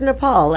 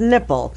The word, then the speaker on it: Nepal: In one case, Trump, while studying a briefer's map of South Asia, ahead of the 2017 meeting with India's prime minister, mispronounced Nepal as Nipple.